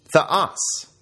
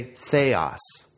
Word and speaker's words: theos: Theos, ha. Now, please don't say theos. Say theos. The accent is on the second syllable, and the epsilon is short. It's not theos, it's theos.